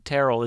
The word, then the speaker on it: Terrell: I believe Terrell is the correct pronunciation.